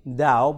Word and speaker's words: Tao: Pronounced with a D, Tao, but spelt with a T.